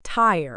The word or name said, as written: tire